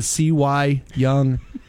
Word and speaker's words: Cy Young: It's Cy Young.